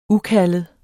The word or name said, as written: ukaldet